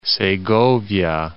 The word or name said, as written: Segovia